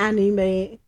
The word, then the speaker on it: anime: The anime, I hope I said that right.